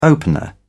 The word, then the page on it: opener: British English